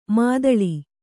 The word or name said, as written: ಮಧೂಳಿ